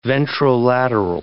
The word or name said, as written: ventrolateral